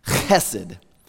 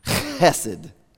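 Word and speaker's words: Chesed (חסד): I have to swallow hard because you got to say it like this. Chesed. Chesed. That's the word. That's a Hebrew word. Now, it doesn't sound so beautiful because it's very guttural and it's like you're coughing up a hairball, but it's a beautiful word in meaning.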